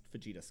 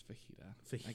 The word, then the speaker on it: Fujita: Fujita scale. I always thought that this was based... – It's pronounced fajita. I can't stand it. – No, fajita has an A. This is a U. Fujita.